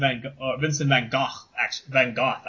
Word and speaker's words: Van Gogh: But I actually really liked their portrayal of Vincent Van Gogh, actually, is how you pronounce it, apparently.